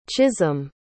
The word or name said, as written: chisholm